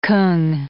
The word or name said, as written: Kung